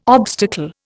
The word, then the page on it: obstacle: Indian